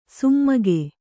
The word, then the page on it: ಸುಮ್ಮಗೆ: summage